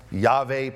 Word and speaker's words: Yahweh: That word, I am, in Hebrew, that tetragrammaton, Yahweh pronounced in Hebrew, Jehovah pronounced in English because we give vowels to it, but it's really called Yahweh.